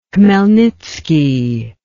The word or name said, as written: Khmelnitsky